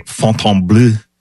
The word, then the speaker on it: Fontainebleau: There's also a Fontainebleau in Miami and now here, right? – That's how you say it.